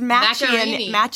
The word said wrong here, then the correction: Macchiarini: Macchianelli? – There's an R in there.